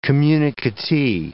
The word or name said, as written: communicatee